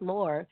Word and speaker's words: Maure: It's pronounced more. It's just my dad actually found my name because it sounded like to him, like more.